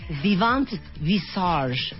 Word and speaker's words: Vivant Visage: vivante Visage. ¿Vieron qué bonita pronunciación en francés? – Vivant Visage.